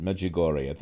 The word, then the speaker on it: Medjugorje: I do pronounce it better when I'm not looking at how it's spelled. Medjugorje.